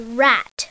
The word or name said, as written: rat